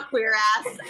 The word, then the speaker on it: cuirass: Wait, it's not cuirass, is it? – It's cuirass! – I refuse to call it a cuirass.